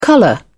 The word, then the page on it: colour: British English